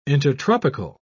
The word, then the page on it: intertropical: British English